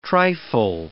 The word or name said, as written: trifle